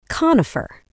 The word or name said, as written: conifer